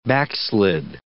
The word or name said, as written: backslid